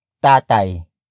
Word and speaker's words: tatay: It is another focus marker which comes before the second main noun of the sentence, and that is tatay, which is spelled as T-A-T-A-Y.